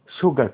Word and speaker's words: sugar: sugar cane. الاس هنا بتنطق شي، sugar cane، السيو مع بعضها كانت بتنطق شي، sugar cane اللي هو قصب السكر اسمه sugar cane.